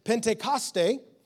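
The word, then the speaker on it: Pentecoste (Πεντηκοστή): The word Pentecost comes from the Greek word Pentecoste, that's really how you say it, meaning 50th.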